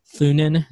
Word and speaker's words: Thunen: Robert L. Thunen. Is that right? Did I say that right? – Yeah.